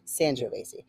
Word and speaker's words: Sangiovese: Sangiovese. – Okay, got it. Maybe it depends on where you're at, where you're from. – Yeah. – Because it's San versus San. Yeah. – Sangiovese is a...